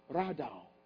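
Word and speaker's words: radaw (רָדָה): This word in the Hebrew language, Genesis 1:20, is the word R-A-D-A-H, radaw. Sometimes it's spelled R-A-W-D-A-W, depending on the Hebrew translator. The word radaw, R-A-D-A-W, do you know what it means?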